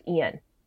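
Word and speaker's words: Eion: But the guy that almost runs Pacey over is Billy, and he's played by, I think it's pronounced Ian, E-I-O-N. I know, it's a tricky one.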